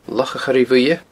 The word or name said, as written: Loch a' Choire Bhuidhe